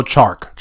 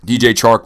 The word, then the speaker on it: Chark: So was impressed with DJ Shark. Chark. DJ Chark, is that how you say it? – I'm gonna go Chark. – Chark. DJ Chark looked great.